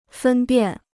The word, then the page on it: 分辨: fēn biàn